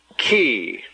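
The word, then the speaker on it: chi: PH, like in phone, phone, or an F-E-E, an F sound as in fee, fee. The next letter is key. Key, just like a door key.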